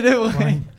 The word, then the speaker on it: De Bruyne: C'est De Bruyne, c'est exceptionnel parce que Evan il a, il a tremblé vraiment à la prononciation, il a pas dit j'ai rigolé et peut-être que Milan m'a vu mais c'était pas tout à fait bien prononcé.